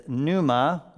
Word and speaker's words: pneuma: The word in Greek is pneuma with a silent P. Think pneumonia.